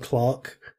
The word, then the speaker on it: clerk: To our British viewers, when we say clerk, we mean Clark. None of you can't pronounce that right yet. It's Clark.